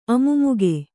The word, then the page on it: ಅಮು್ಮುಗೆ: Kannada